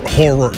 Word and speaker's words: horror: I can't watch horror movies, but there was something about the Saw movies. – What movies? – What did I say? Horror. – Two syllables. – Horror. Thank you.